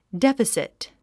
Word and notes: deficit: défəsit; デファシト

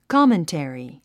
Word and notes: commentary: kάməntèri; コォメンテリィ

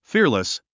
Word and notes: fearless: fíə r ləs; フィアレス

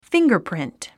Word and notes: fingerprint: fíŋgərprìnt; フィンガァプリント

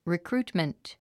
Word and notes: recruitment: rikrúːtmənt; リィクルートメント

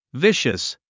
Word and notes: vicious: víʃəs; ビシャス

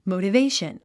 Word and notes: motivation: mòutəvéiʃən; モゥタベイション

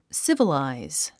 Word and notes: civilize: sívəlàiz; スィバラァイズ

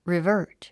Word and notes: revert: rivə́ːrt; リバート

